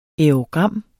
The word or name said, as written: aerogram